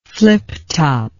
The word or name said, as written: fliptop